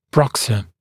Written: bruxer

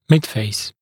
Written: midface

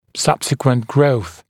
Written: subsequent growth

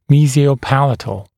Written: mesiopalatal

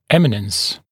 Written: eminence

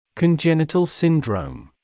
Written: congenital syndrome

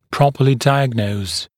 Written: properly diagnose

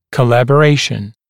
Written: collaboration

